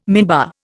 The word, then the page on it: minbar: Indian